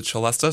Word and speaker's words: celesta: In the liner notes for the album, it's just referred to as the celeste, C-E-L-E-S-T-E. Some people pronounce that celeste as well.